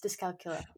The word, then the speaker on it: dyscalculia: So we mentioned it slightly earlier when you were talking about that you had dyscalculia. Am I saying that right? – Yes, dyscalculia, yeah.